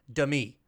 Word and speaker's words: Demi: And, you know, I honestly think I go back and forth, but it is pronounced, I checked, Demi. – So, Demi Moore. You mean like semi truck? – Yeah, like a semi truck, semi tractor trailer.